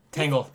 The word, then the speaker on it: Tangle: Tangle. With an A. With it a long A.